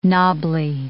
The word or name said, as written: knobbly